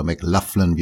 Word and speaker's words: McLaughlin: Paco de Lucia und John McLaughlin oder McLaughlin, wie er ausgesprochen wird, zu dritt an akustischen spanischen Gitarren haben sie den Mediterranean Sundance gespielt.